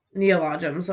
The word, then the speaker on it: neologisms: Hubbard developed thousands of Neologisms. – Okay. I was putting the emphasis on the wrong syllable in my head.